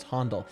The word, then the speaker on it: Handel: Wait, are we saying Handel or Handel? – Handel. – Handel? – Because it's a German name. It's Handel.